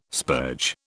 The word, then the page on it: spurge: British English